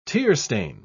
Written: tearstain

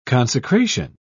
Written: consecration

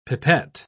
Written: pipette

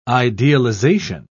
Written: idealization

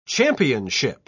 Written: championship

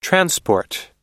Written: transport

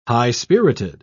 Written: high-spirited

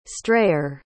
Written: strayer

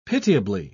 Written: pitiably

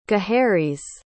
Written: Gaheris

